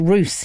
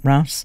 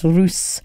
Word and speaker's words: Rouse: He is, it's Josh Rouse, he pronounces his name, not Rouse. Spelt Rouse, but pronounced Rouse.